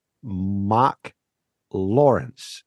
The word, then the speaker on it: Marc Lawrence: invite Marc Lawrence. Is that correctly said? Because I'm Danish, so I don't. Is that correctly said? – Did I pronounce it? Spot on. Spot on.